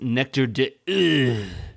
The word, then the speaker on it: Nectar d'Or: I believe it's pronounced Nectar d'Or. – That is the correct French pronunciation, yes.